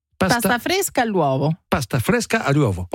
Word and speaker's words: pasta fresca all'uovo: Les pâtes fraîches aux œufs, je vais essayer de le prononcer en italien. Paste fresca all'uovo. Alors j'étais bien parti. – Paste all'uovo. Pasta fresca all'uovo.